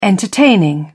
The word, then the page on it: entertaining: British English